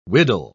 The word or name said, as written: widdle